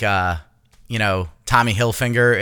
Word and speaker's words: Hilfiger: Hilfiger. – Tommy Hilfiger. – There's no N.